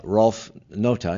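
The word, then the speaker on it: Ralf Nauta: You, along with your artist partner, Rolf Nota, is that how you say his name? – That's how you say it, yeah.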